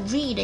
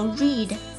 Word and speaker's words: read: Read between the lines, 要注意歌词中的 read 要读成 read, 因为阅读 read 过去式和现在式呢是一样的，不过读音要由 read 读成 read。